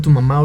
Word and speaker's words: Tu Mamá: y en Spotify nuestras rolas las encuentran así como Tu Mamá, obviamente con el acento en la última A.